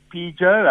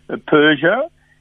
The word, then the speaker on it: Peugeot: Do you know, of course we know, by the way, some people say Peugeot. I think the right pronunciation apparently is Peugeot.